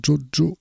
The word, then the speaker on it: Tjotjo: On va continuer toujours dans les nouveautés, cette fois-ci avec Jojo Records. Ça, je le dis comme ça parce que ça s'écrit T-J-O-T-J-O, Jojo Records, Big World for My Little Bird, et donc c'est de Netherlands.